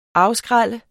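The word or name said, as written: AFSKRÆLLER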